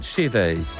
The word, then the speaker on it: Shivers: Allora, essendo lui inglese, quindi britannico, sì. Ed Sheeran con Shivers. Shivers. – Shivers. – Shivers. Se fosse americano, Shivers.